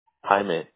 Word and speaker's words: Jaime: No, my first name is Jaime, J-A-I-M-E.